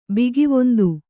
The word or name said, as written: ಭಗವಂತ